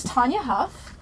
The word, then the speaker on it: Tanya Huff: The author is Tanya Huff.